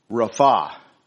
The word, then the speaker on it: Rapha: Actually, the correct Hebrew pronunciation is Rapha, accent on the second syllable.